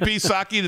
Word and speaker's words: Psaki: Jen Psaki, the P is silent, in the briefing room.